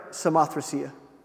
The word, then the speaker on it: Samothracia: Acts 16, verse 11 says, therefore, loosing from Troas, means they set out, we came with a straight course to Samothracia, is how I'm going to pronounce that, if you don't mind. How would you pronounce it, Eric? Is that right? Samothracia. Not Samothracia?